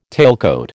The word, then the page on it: tailcoat: American English